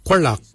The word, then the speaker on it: gwelyag: For your hips, we say Qualak. Qualak is your hips. Qualak is spelled G-W-E-L-Y-A-G.